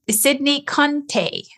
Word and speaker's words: Sydney Conteh: This week, we will dive into diverse topics that reflect the rich spectrum of human experience with Sydney Conteh.